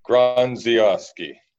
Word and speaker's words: Grondziowski: Grondziowski.